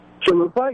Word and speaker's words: Chalupa: is it Chalupa? – Chalupa, yeah.